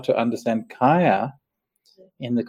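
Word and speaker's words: kāya: Okay, so kaya. Okay, so in the Pali word kaya, K long A Y A is from the root meaning, root from the root chi, as it happens.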